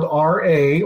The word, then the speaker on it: Ra: But then I was like, no, let's just stick with the R-E because R-E is also spelled R-A or pronounced as Ra in Egyptian mythology.